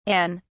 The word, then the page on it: Anne: British English